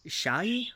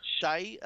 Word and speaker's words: Shahi: Uh, we got uh, Sarah, how do you pronounce her last name? Shahi. Shahi, uh, starring as Nancy Drew in CBS pilot.